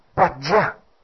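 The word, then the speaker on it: Prajñā: 那这个字在梵文里面，这个 Prajna 这个字呢，我们如果像般若这两个字啊用台语 Prajna、哦、就更接近梵音啊，就台语发音叫 Prajna 啊，所以我们以后念心经的时候呢，我们请维诺要念这呢叫 Prajna 啊啊，希望你慢慢的习惯啊。当然念般若也没有错啦啊，念般若也没有错，不，我的意思说我们如果就梵音来讲的话， Prajna 是比较接近的，也就是 Prajna 啊 Prajna。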